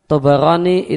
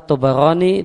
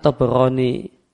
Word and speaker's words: Tabarani: Dan yang benar Tabarani itu dengan fathah, dengan fathahnya ba, tidak dengan sukunnya ba. Yang benar bacanya adalah Tabarani dan bukan Tabarani. Ba-nya di fathah bukan di sukun.